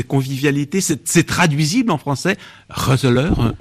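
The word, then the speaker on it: gezellig: Qu'est-ce que c'est que ce fameux Roseleur qu'on écrit en français G-E-Z-E-2-L-I-I-G? – Oui, ça se prononce Roseleur.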